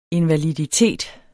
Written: invaliditet

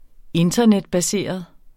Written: internetbaseret

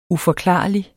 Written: uforklarlig

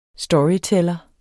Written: storyteller

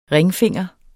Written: ringfinger

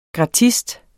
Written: GRATISTEN

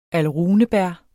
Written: alrunebær

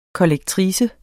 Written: kollektrice

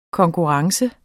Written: konkurrence

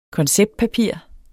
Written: konceptpapir

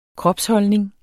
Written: kropsholdning

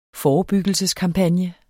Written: forebyggelseskampagne

